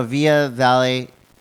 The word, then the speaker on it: Via Vale: It's V-I-A-V-A-L-E.